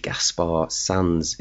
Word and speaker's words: Gaspar Sanz: That was called I Swim, the name of the band, Gaspar Sanz. It's spelled G-A-S-P-A-R-S-A-N-Z.